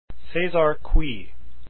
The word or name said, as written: cui